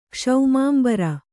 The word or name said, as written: ಕ್ಷೌಮಾಂಬರ